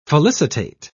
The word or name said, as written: felicitate